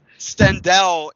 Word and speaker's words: Stendhal: Stendhal is how the guy on the commentary pronounces it.